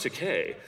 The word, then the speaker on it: Takei: It's pronounced Takei.